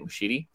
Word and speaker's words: Muschietti: I think that's how you say it, Muschietti.